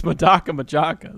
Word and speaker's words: Madoka Magica: Yeah, because like I bought the trial deck and I was like, it's Madoka Magica.